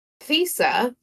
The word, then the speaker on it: FISA: Now, I say new super license contracts because over the winter break, FISA, sounds like Visa because it's spelled like Visa with an F, which was the precursor to the FIA, decided to create some newer clauses to their super license regulations.